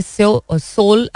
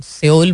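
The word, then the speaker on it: Seoul: सोल actually होता है, लोग सियोल बोलते हैं, but सोल is the way they pronounce it.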